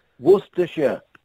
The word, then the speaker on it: Worcestershire: Is it Worcestershire sauce? – Worcestershire. – Worcestershire. – Hang on, that's a silence. – Worcestershire sauce. – Is the C silent? – Worcestershire. Yeah, Worcestershire.